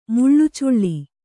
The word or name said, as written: ಮುಳ್ಳುಚುಳ್ಳಿ